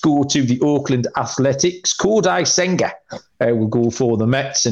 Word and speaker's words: Kodai Senga: And by the way, can I just say you pronounced that amazingly, Kodai Senga. So props.